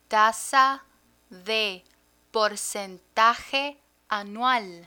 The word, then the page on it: tasa de porcentaje anual: Spanish